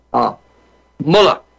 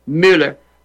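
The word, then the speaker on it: Muller: We will have as our guest Gustav, and I'm going to pronounce it the Afrikaans way. I worked on this. Mulla. Gustav Mulla. And Simon Roach, if you're out there, check me on my pronunciation. He said it's a flat U, Mulla. Not the German, Müller, but Gustav Mulla.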